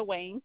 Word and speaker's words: Wang: Yes, it's Melissa Wang, WANG hyphen now, MAO.